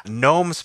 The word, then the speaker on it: gnomes: Gnomes play a big feature in your your videos and your name. It's not Nomadic. It's got a silent G at the front of it.